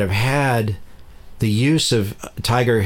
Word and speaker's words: TIGAR: It's T-I-G-A-R. It's not like the animal. It's TIGAR. but it's pronounced Tiger.